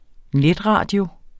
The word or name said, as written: netradio